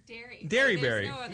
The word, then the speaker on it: Derryberry: Derryberry.